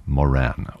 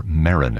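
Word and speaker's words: Moeran: Moran, or Marin, if you prefer.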